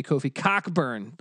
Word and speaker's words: Cockburn: And they go a lot with the 1-2 punch of Dosunmu, who's just a complete beast, and then Kofi Coburn, aka Kofi Cockburn, the way it's pronounced.